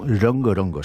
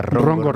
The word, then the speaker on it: rongo-rongo: Es como, rongo-rongo, es como lo dicen. No, yo siempre decía rongo-rongo. – Rongo-rongo, sí, claro. Y lo pronuncian rongo-rongo, o sea, con una R en lugar de una R.